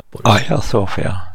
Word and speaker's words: Hagia Sofia: Frågan är varför man skriver Hagia Sofia. Aya Sofia är det grekiska uttalet. Men av någon orsak så translitterar man med H-A-G.